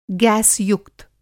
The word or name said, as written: गैस युक्त